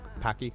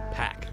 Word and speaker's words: Paak: Packy. – Pack. – Maybe not Packy. – I've heard it pronounced Pack.